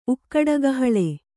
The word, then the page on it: ಉಕ್ಕಡಗಹಳೆ: Kannada